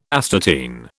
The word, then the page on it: astatine: British English